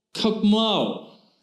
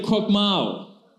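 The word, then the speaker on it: chokmah (חכמה): In fact, when Solomon is writing about wisdom, he uses a specific word in the Hebrew language, and it's this word, chokmah. Everyone say chokmah.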